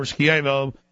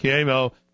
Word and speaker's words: Schiavo: And for those who just joined us, Terry Schiavo, or Schiavo, family pronounces it Schiavo, Schindler is, they're trying to kill her.